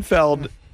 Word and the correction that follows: Pinfield: man, I just went to his... – Pinfield. – A Pinfield. I went to his Wikipedia. I always called him Pinfeld. – That could be wrong. – It's spelled... – It is spelled Field.